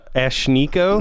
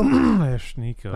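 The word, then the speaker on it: Ashnikko: Ashniko. – Ashniko. – I don't know how to pronounce it. It's A-S-H-N-I-K-K-O.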